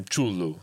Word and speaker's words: Cthulhu: Yeah, it's like I've been told that the actual pronunciation for Cthulhu is Chulu or something near that.